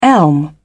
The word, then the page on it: elm: British English